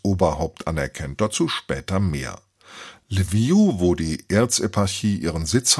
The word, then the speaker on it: Lviv: Lviv ist der ukrainische Name, auf Englisch wird er Lviv ausgesprochen. Die Stadt ist auch bekannt als Lemberg oder auf Polnisch als Lwów.